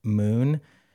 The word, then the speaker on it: Muun: If you have your phone, uh-huh, and you go download an app called Moon, confusingly, it's spelled M-U-U-N. It's one of my favorite. – M-U-U-N.